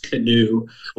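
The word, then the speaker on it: Knu: So then it kind of spiraled into a joke from there, pronounced canoe, like the boat and the oar and stuff like that.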